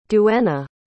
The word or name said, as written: duena